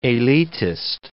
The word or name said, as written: elitist